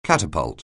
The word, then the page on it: catapult: anglais